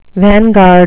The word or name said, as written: vanguard